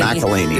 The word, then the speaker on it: McElhenney: Ways to massacre and mispronounce it, there are many. – McElhenney. – It's McElhenney.